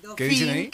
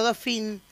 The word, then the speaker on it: Dauphine: Hoy temprano, es el Dauphin, que es parecido al Gordini. – Claro. – Ah. ¿Cuál? – El Renault Dauphin. Ajá. Dauphine se escribe.